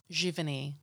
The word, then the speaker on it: Giverny: Giverny. – Giverny. – So it's French. It's a French name. The French say Giverny.